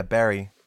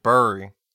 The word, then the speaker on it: bury: how do you say it? mm Barry? Yeah, Barry. Well, that's spelled B E R R Y. – No, you said it like Burry.